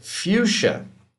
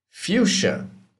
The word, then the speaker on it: fuchsia: And there is question whether fuchsia has no sound to the CH or not, because it's spelled F-U-C-H-S-I-A. So there's kind of a jumble of letters there that make it difficult to tell.